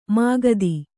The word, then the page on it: ಮುಕುತ: kannada